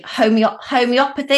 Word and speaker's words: homeopathy: So I must admit, before I heard you explaining this, I assumed it was, I can never say this word properly, homeopathy.